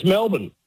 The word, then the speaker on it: Melbourne: Now it's pronounced to us Aussies, dead set Aussies. It's just Melbourne. So we drop most of the letters, and it's just the M E L.